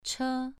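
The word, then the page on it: che: tone 2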